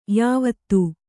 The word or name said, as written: ಯವತಿ